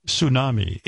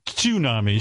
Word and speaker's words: tsunami: You said tsunami. It's tsunami, so I have to take away a point. – Musculature. – Yeah. It's musculature. – Tsunami. – Tsunami. – Tsunami. Tsunami. – You didn't pronounce the T, and I'm taking away points.